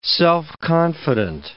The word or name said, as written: self-confident